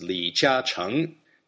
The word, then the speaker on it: Li Jiacheng: In Mandarin, his name is pronounced Li Jiacheng.